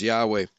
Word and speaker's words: Yahweh: It's correct, the pronunciation is Yahweh.